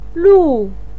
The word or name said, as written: lu4